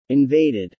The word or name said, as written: invaded